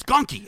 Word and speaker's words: Gonky: He's like, it's Gonky. It's Gonky. Just say it right. It's Gonky. And I'd be like, it's Gronky. But you're right. It is Gronky. It is.